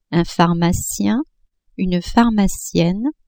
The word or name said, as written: pharmacien-pharmacienne